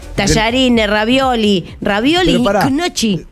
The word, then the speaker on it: gnocchi: Que la pronunciación de gnocchi en italiano es gnocchi.